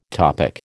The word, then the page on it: topic: American English